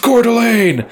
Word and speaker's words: Coeur d'Alene: Pronounced it very, she gave it a much more French, and obviously it's from the, it is French, but yeah. – I imagine anybody in Idaho watching was probably it's pronounced Coeur d'Alene.